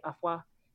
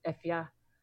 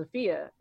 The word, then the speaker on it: Afua: So that's also what confuses a lot of people, because some Ghanaians will call me Afua, and that's not how you pronounce it. It's Efia. And so the English version is Afia.